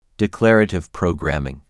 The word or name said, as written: Declarative programming